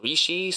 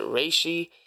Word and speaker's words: Reishi: The first one is known as Rishi. Some people pronounce it Reishi. Um, and of course, that's not the real name for it. it has a pinyin name, a, a, Chinese name like Ling Zhi.